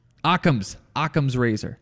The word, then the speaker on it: Occam's razor: Axum's razor. It's a razor. I'm saying it wrong. Occam's razor.